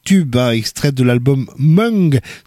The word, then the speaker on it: Mwng: Eh bien, voilà, c'est comme ça que ça s'écrit, M-W-N-G, ça se prononce Mung.